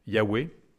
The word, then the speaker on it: Yahweh: La syllabe « Yah » étant un diminutif du nom de Dieu, qu'on prononce « Yahweh », et qui veut dire « Je suis ».